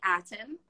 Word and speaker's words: Aten: And today on Yoga Corner Chats, we are joined by Becky Aten.